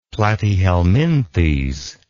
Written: platyhelminthes

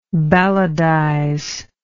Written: balladize

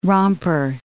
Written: romper

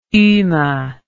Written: ume

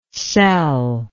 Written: celllike

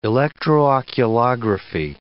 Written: electrooculography

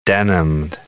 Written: denimed

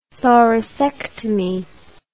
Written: thoracectomy

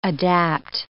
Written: adapt

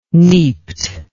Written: neaped

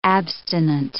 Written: abstinent

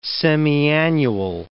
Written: semiannual

